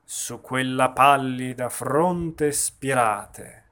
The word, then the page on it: Ondine e Silfidi: espressivo